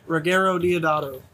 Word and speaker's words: Ruggero Deodato: Ruggero Deodato.